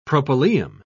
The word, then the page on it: propylaeum: British English